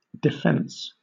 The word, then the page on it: defence: British English